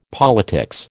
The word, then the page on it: politics: American English